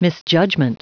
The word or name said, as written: misjudgment